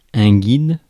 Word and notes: guide: /ɡaɪd/

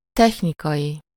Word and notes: technical: American English; /ˈtɛk.nɪk.əl/

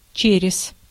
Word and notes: in: /ɪn/; [ɪn]

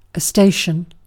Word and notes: station: /ˈsteɪʃən/

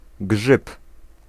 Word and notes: mushroom: American English; [ˈmʌʃ.rʊm]

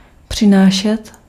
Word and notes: rapporter: France; [ʁa.pɔʁ.te]

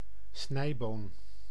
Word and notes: haricot: /a.ʁiˈko/

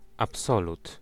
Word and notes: absolu: /ap.sɔ.ly/

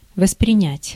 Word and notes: воспринять: /vəsprʲɪˈnʲætʲ/; vosprinjat

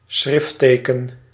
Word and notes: schriftteken: /ˈsxrɪf.tə.kə(n)/

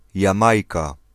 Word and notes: Jamaica: American English; [dʒə.ˈmeɪ.kə]

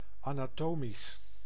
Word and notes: anatomique: France; /a.na.tɔ.mik/

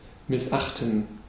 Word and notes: missachten: /mɪsˈʔaχtən/; /mɪsˈaxtən/

